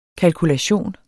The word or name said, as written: kalkulation